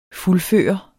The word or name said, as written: fuldføre